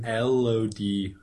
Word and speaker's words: Elodie: Elodie Young.